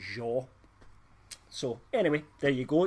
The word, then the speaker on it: Zhao: It's actually pronounced Zhao. So it's Chloe Zhao.